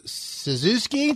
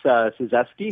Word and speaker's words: Suzeski: Suzuki? Did I get it right? Ed Suzuki? – That's definitely how it looks. It's Suzeski. – Suzeski. – Close. Americanized Polish.